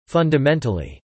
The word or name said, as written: fundamentally